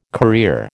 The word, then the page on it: career: American English